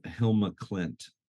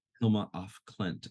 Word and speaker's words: Hilma af Klint: She's a Swedish painter, Hilma Klint. Hilma af Klint, I think was her name.